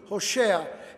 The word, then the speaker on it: Hosea: But it's interesting that the prophet's name, Hosea, that is how it is pronounced in Hebrew, Hosea,